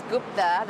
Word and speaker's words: Gupta: My name is Deepthi Gupta. – Ah, that's an interesting name. Could you spell it for me? – Yes. The first name is Deepthi, which is D-E-E-P-T-I. And the last name is Gupta, that's G-U-P-T-A.